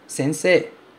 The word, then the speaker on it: sensei: Bom, uma coisa interessante sobre essa palavra sensei é que ele se escreve com EI. Sensei. A gente escreve sensei, mas a gente fala sensei. Esse EI do final tem som de E.